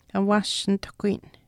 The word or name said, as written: awaashnituhkuyin